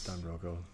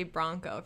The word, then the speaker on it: Don Broco: It's Don Broco. – They're very interesting. – I say it, but I say it wrong. I usually say Bronco for some reason.